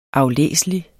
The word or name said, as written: aflæselig